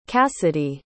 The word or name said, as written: casidhe